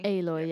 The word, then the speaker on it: Aloy: I'm glad that you pronounced so it's Aloy. – Aloy, yes. Yeah. – Aloy, because I've been referring to her as if she was a metallic substance composed of two or more metals.